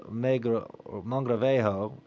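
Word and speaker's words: Mogrovejo: And Toribio Alfonso de Mangravejo, is how you pronounce his name as in Spanish, died...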